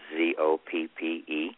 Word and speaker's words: Zoppe: My last name is spelled Zoppe,